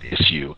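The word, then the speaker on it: Issuu: I pronounce it issue, ISSU, or you can read it on your iPad.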